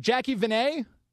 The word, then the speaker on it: Jacqi Vene: Is it Jacqi Vene? – Yes, exactly.